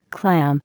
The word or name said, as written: clam